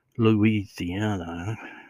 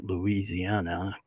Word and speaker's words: Louisiana: Now, for those who live outside of Louisiana, you pronounce it Louisiana, but if you live inside, it's Louisiana.